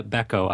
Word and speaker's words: Bechko: Karina Bechko. I apologize. The H is silent.